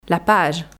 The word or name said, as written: la page